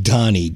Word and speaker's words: Dhani: It was produced by his son, which I believe is pronounced Donnie, D-H-A-N-I.